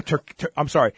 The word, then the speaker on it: Turkesterone: I believe there is also a new version they call Exumid, which also contains Turkestan. Oh, no, Turkesterone.